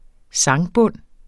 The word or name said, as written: sangbund